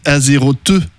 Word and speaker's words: Azeroth: On dit Azeroth. Azeroth.